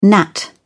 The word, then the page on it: gnat: British English